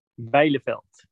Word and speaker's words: Bijleveld: Bijleveld.